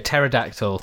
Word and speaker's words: Pterodactyl: Pterodactyl. – Pterodactyl. – I love it because it has a silent P in its name.